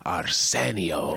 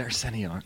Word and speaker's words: Arsenio: I may have said this on this podcast before, but Arsenio, he would You say his name like Ricardo Montalban. He used to always love, Montalban would say Arsenio. And you have a strong Enio. – Oh, okay, Arsenio. – You say it properly.